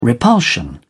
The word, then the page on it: repulsion: British English